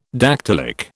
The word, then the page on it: dactylic: British English